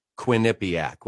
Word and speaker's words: Quinnipiac: Yeah, my my version would be Quinnipiac with the emphasis on the Quinn at the beginning.